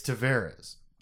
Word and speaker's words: Tavares: When he showed up, he was like, "Hey, it's Tavares." And then like the next year, they asked him again, and he was like, "Hey, it's Tavares."